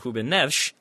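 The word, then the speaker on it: Rúben Neves: Say Adama Traore does, or even like in a more key position, like say Ruben Neves. I've got to go with the actual Portuguese pronunciation there because I'm pretentious.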